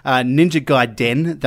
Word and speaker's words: Ninja Gaiden: Ninja Gaiden, the Master Collection. – Yeah. Is that how it's pronounced? – Yes. That's what I'm saying anyway. Yes, they're getting a master collection of all 3 of the Gaiden games, which is pretty awesome.